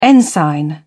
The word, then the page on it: ensign: British English